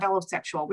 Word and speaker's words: allosexual: And on the other side, you have allosexual, which is just regular, plain old sexuality in all of its forms.